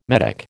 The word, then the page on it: Medoc: American English